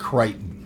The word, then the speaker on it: Crichton: It's pronounced Crichton.